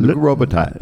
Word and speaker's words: Luc Robitaille: ähm, ähm tätig und ähm, ja, Luc Robitaille, äh, wie spricht man es richtig aus? Hast du gut gemacht, ja, Luc Robitaille.